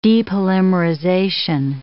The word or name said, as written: depolymerization